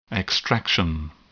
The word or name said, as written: extraction